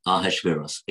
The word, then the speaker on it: Achashverosh: The name Ahasuerus is spelled A C H A S H V E R O S H, Ahashveros A C H A S H V E R O S H.